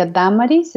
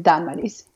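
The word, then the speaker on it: Dámaris: Sí, mi nombre se pronuncia Damaris, se escribe también con acento o la tilde en la primera A, y así es como se pronuncia mi nombre.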